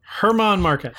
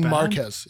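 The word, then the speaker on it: Germán Márquez: Tell me, say his name again. – German Marquez. – German. – I didn't butcher German it. – German Marquez. Yes. Yes. Okay.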